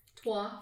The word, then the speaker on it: trois: Ménage à trois. – Trois. It's spelled in a French way, but if you can TWA is how you could spell it mentally as an English speaker.